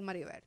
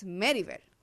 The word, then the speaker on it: Meribel: Pero realmente es culpa de mami, porque ella me debió poner el acento en la E cuando lo escribió porque ella lo saca de, el nombre viene de Francia, es Meribel. Pero ah.